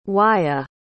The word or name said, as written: wyat